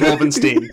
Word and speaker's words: Wolfenstein: It's pronounced Wolfenstein.